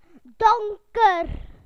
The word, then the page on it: dark: British English